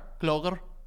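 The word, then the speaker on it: Clogher: It's a C-L-O-G-H-E-R. Kloger.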